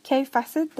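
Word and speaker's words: Kaffe Fassett: Kay Facet, det skrives da for øvrig Kaffe Facet. For, Men kaffe eh, uttales Cafe. Ja, det gjør faktisk det.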